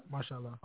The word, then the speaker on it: Mashallah: Mashallah.